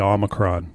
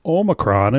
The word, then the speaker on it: Omicron: So under no circumstances are you allowed to say Omicron, guys. It has to be a long O. The Greek pronunciation of O is O. So, listeners, you are now no longer allowed to say Omicron. It has to be Omicron.